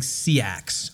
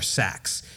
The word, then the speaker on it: seax: He makes a knife called a Viking sea axe or sax. – Okay. – It's spelled S-E-A-X. – So butch. – It's S-E-A-X is how it's spelled.